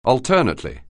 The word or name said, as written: alternately